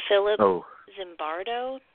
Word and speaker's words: Philip Zimbardo: It's called The Lucifer Effect by Philip Zimbardo.